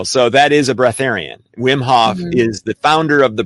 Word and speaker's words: Wim Hof: Is it Wim Hof? I think I'm saying it right. – Wim Hof. – That's it.